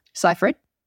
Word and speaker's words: Seyfried: Seyfried? – Yeah.